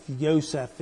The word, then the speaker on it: Yosef: Yosef is the way it's pronounced in Hebrew.